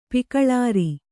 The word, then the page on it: ಪಿಕಳಾರ: pikaḷāra